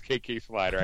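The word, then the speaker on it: K.K. Slider: KK Swiss is in his house, yo. It's KK Slider. I could never say his name right. I always call him KK Swiss or KK Dog, K Dog.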